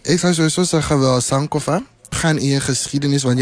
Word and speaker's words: Sankofa: Sankofa. Dat is... – Zo spreek je het uit. – Ja, zo zeg je het eigenlijk. Sankofa. – Sankofa.